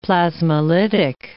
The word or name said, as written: plasmolytic